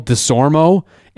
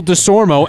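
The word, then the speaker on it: DeSormeaux: E-A-U-X? – Yeah. – That's how his name is spelled. Michael DeSormo, M-E-A-U-X at the end.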